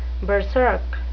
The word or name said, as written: berserk